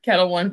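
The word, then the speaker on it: Ketel One: Kettle One Vodka.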